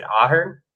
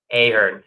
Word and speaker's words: Ahern: Is it Ahern? – It's Ahern. – Ahern.